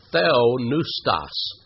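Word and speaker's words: theonoustos (θεόπνευστος): Now, in Greek, that word inspired is a compound Greek word, theonoustos. Theos, you already know what that means, don't you? Theos, God. Noustos, spelled with a P-N-E-U, like pneumatic or pneumonia.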